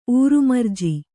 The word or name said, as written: ಊರುಮರ್ಜಿ